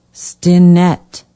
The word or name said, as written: stinnett